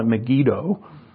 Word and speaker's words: Megiddo: I looked up the pronunciation. I call it Megiddo. Mosquito.